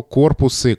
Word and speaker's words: корпусы: А корпус в интересующем нас значении, а это значение совокупность чего-то, в данном случае совокупность текстов, имеет множественное число корпусы корпусов. Поэтому мы сейчас будем говорить о корпусах русского языка, и здесь уже это ударение сохраняется на первом слоге во всех формах.